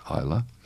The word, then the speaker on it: Isla: With an S, Isla. The silent S, like P, in Bath.